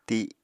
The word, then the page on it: ti: Apatani